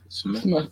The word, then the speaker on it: Portsmouth: Did I say Myth? – Smith? That's how it's pronounced.